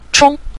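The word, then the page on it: chong: tone 5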